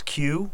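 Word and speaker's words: queue: So what word begins with a Q and is followed with four silent letters? – It is... – we had this before. – Yes, and I want to say it's Q, mm-hmm. like a line. – Yeah, and you spell it... – Q-U-E-U-E-U-E-U-E. – Great Scrabble word. – Right. You could just say Q. And you could even just get away with, C-U-E, like a pool Q.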